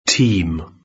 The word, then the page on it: team: British English